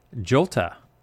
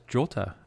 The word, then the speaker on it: jota: Jolta. Now, where does Jolta come from? J-O-T-A. Well, apparently that's how you pronounce the letter J in Italian.